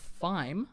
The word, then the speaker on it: thyme: And coming to cinemas this week, you have Wild Mountain Thyme, I think that T-H-Y-M-E, it's like rhyme but with a T.